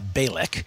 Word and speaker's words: Bejlek: So on the lady side, and this is in Austin, Texas, uh, I am on Sarah Bailey, uh, Balik. Let me tell you how that's spelled. It's spelled like Sarah Bejlek, but it's pronounced Sarah Balik.